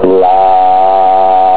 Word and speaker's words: Larra: Mantra Larra, se pronuncia así, Larra.